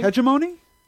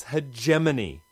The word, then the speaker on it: hegemony: Hegemony? – Oh, jeez. No wonder it's frayed around the edges. Words are not meant to stand up to their secondary pronunciations. It's hegemony. Hegemony.